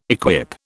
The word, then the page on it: equip: British English